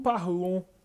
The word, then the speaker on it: parlons: O, esse S, de novo, ele não é pronunciado. Nous parlons.